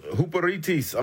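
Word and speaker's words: huperitis (ὑπηρέτης): Servants is the Greek word, and it's pronounced huperitis. I'm going to spell it for you because I want you to get this. Huperitis. H-U-P-E-R-E-T-E-S. I say again, H-U-P-E-R-E-T-E-S.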